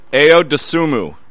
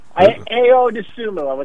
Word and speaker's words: Ayo Dosunmu: Ayo Dosunmu. – Ayo Dosunmu, with a name like that, how can you go long?